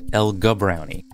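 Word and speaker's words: El-Gabrowny: Before the break, we heard Emad Salem explain how he was able to gather evidence against a man he was friendly with, Ibrahim El-Gibrani, although some people pronounce it El-Gubrani.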